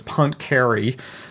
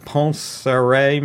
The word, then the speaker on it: Poincaré: An American would read that as Poincaré, but Poincaré maybe is how he pronounced his name.